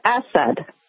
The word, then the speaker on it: Assad: Assad.